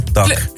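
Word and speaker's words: DAC: Ik was toch even benieuwd hoe je dat moest uitspreken. Maar het is de afkorting van Duna Ceradali Atletica Club. – Ja, wel. DAK.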